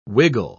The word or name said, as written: wiggle